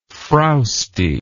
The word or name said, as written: frowstiness